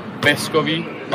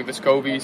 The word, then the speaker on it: Viscovi: Back home, it would be Viscovi. Like the accent would be on the first E instead of the O, like people say here.